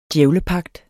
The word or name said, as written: djævlepagt